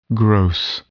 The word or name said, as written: gross